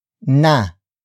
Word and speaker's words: na: Another example, huwag na huwag kang aalis. Huwag na huwag kang aalis. Huwag na huwag kang aalis. It means don't you ever leave. Word for word it's huwag na huwag kang aalis.